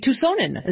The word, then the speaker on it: Tucsonan: A Tucsonan.